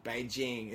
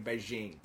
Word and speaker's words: Beijing: Do you know what the only thing that Beijing Olympics changed in Australia was everyone started saying Beijing instead of Beijing.